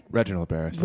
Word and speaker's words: Reginald Bärris: Reginald Bärris.